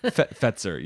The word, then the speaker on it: Fetzer: So had a Gewürztraminer from a California Fetzer. Winery. – I don't know. Did I say it right? – Fetzer. Yes.